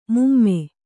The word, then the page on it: ಮೂಮೆ: Kannada